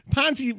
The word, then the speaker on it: Ponzi: Ponzi rhymes with Fonzi, and that makes it cool.